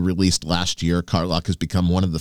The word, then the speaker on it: Karlach: Carlock. K-A-R-L-A-C-H. That'd be Carlock.